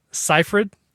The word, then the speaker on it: Seyfried: And there's this moment where Amanda Seyfried is... That's how I said it, right? I said it right? – You did it.